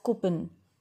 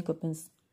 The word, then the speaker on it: coupon: Generally what happens, we pronounce this word as coupon. Coupon. No, we generally say I have many coupons. But the correct pronunciation of this word, it's coupon.